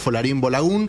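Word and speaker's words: Folarin Balogun: Porque esto, a ver, para quien no sabe, a un jugador de Estados Unidos, Folarin Bolagún, que fue expulsado en el último partido. – ¿Cómo pronuncia? – Sí, muy bien.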